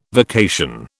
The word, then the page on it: vacation: British English